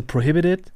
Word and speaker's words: PROHIBITED: Also so, der, so richtig Englisch-Englisch wäre eigentlich so prohibited. Prohibited. So mit, okay. mit, so einem D quasi schon statt des Ts. In Deutschland ist eher dann so prohibited, so mit dem scharfen T.